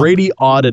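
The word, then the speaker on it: Radioddity: That's Radiodity? I think I just said it wrong. – Radiodity. – Radiodity. Yeah.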